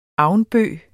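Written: AVNBØG

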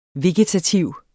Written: vegetativ

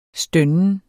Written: stønner

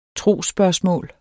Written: trosspørgsmål

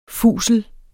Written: fusel

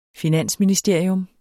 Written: finansministerie

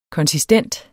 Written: konsistent

